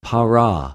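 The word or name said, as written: παρα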